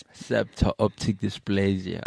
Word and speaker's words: septo-optic dysplasia: Septo-optic Dysplasia.